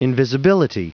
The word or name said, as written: invisibility